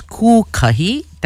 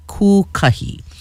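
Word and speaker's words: Kūkahi: Today, Monday, October 13th, the Hawaiian moon phase is Kukahi. That's spelled K-U with the kahako or the macron over the U. K-A-H-I. Kukahi.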